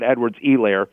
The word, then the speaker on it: Edwards-Helaire: I've been told that the H is silent, that it's that how it's pronounced is Clyde Edwards-Helaire.